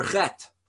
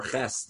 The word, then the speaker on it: Chet: known as the letter Chet, or Ches, depending on where your Ashkenazic or Sephardi pronunciation.